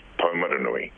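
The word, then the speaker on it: Taumarunui: Well, I'd probably say a Pākehā accent, but yes, the correct pronunciation for the town is Taumaranui, and your canary just fell down the poop.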